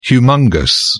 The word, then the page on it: humongous: British English